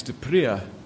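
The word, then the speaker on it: De Pree: One of the other authors who has written books on this type of leadership, the style of leadership, is someone called Max Dupree. We would probably pronounce it Max Dupree in South Africa, but anyway. Max, P-R-E-E.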